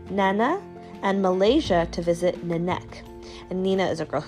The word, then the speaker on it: Nenek: So on one side, it's Nenek. So it's spelled Nenek, N-E-N-E-K, but it says, the K is there and not, like a ghost. Start saying the K, but stop suddenly before any sound comes out.